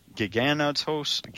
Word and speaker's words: Giganotosaurus: Mike, this dinosaur is depicted as being attacked by a Gigantosaurus. Did I pronounce that correctly? – Yeah, that'll do.